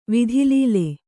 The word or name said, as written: ವಿಧಿಲೀಲೆ